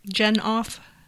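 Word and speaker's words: Jenoff: Is that how you say it? – Genoff. – Like Genoff. Yeah, that's what we're gonna do.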